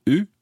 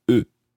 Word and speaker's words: eu: You have had, the formal form or the plural form, vous avez eu. And they have had, ils ont eu.